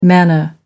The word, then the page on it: manna: American English